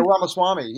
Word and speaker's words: Ramaswamy: is how you say his name. Yeah, Ramaswamy. Yeah, Ramaswamy.